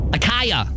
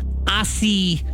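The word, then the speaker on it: açaí: See, I would have said that too when Kat tells me to go get that drink or whatever, but it's acai is how you say that one.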